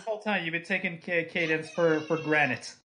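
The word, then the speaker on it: Cadance: It's Cadence, not Candace.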